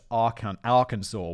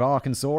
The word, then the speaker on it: Arkansas: I once pronounced Arkansas, where Bill Clinton's from, as Arkansas, and everyone was like, ah, Arkansas?